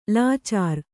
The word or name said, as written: ಲಾಚಾರಿ